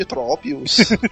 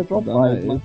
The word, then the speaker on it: Tropius: No próximo carnaval, o Manel vai de Tropius. – Na verdade, a pronúncia certa é Tropius.